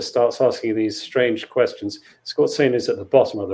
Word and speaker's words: Skorzeny: It's Skorzeny. I think Skorzeny is how most people, probably most Germans, pronounced it when not educated. So Skorzeny. Anyway, eventually he finds himself, he's hauled into a room with five others, I think it is.